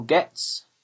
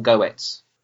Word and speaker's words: Getz: Martyrs 2015, directed by Kevin Getz and Michael Getz. Getz or Goetz? – Getz. – Getz, okay.